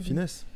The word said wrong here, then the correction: Fiennes: En anglais, c'est Fiennes.